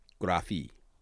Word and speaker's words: graphe: The ancient Greek word for writing or drawing was graphe, graphi, long E.